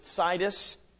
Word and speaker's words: Sidis: Ran across a fellow by the name of William James Sidis, or Sidis. I'm going to pronounce it Sidis.